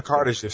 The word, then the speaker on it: Kardashev: Because when I listen to it, how's it pronounced? The Kardashev? – The Kardashev scale. – Kardashev, okay, thank you.